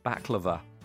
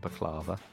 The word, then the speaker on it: baklava: Yeah, apparently we were pronouncing that wrong the other day on the football ramble, for those who heard. Baklava is how you say it, not baklava.